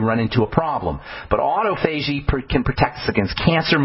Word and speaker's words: autophagy: It's one of the best ways to stimulate autophagy. That's how you say it.